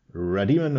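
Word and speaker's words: Rademon: Radimon Estate. Habe ich es diesen Mal gut ausgesprochen? – Radiemann hört sich gut an. Ja, genau.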